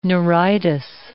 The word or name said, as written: neuritis